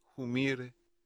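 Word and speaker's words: Humire: Joseph Humer Humair, pero como se pronuncia originalmente en Bolivia, Humir, es uno de los funcionarios más importantes del Pentágono, como se conoce al Departamento de Defensa de Estados Unidos.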